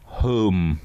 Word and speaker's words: Holme: I did a gig in a place in Yorkshire, which is spelled H-O-L-M-E, but yeah, pronounced home.